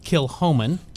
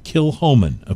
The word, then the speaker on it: Kilchoman: The whiskey's pronounced Kilhoman. – Kilhoman, okay. – Exactly how it's not spelled. – This is spelled, by the way, Kilchoman.